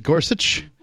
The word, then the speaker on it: Gorsuch: I pronounce it Gorsuch.